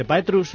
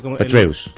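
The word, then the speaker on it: Petraeus: ¿Petrus o cómo se...? Petreus. Petreus, Es, David em, Petreus, el, director de la CIA que ha dimitido por un affair.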